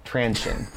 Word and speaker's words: Transsion: I think I pronounce it Transsion. – Transsion. Okay. – I don't know. – Like traction. Transsion. All right.